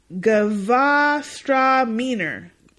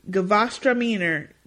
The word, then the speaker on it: Gewürztraminer: G-R-I-S. – Okay. – And Gewürztraminer. – Spell it. – Gewürztraminer. G-E-W-U with the little dots on it because it's German. R-Z-T-R-A-M-I-N-E-R.